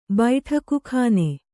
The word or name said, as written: ಬೈಠಕಖಾನೆ